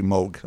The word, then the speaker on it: Moog: I always say Moog because that's how I grew up saying, but it's actually Moog. I heard him say it in a documentary. It rhymes with Vogue.